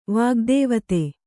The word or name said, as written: ವಾಗ್ದೇವತೆ